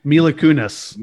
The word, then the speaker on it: Mila Kunis: Kunis? – Mila Kunis.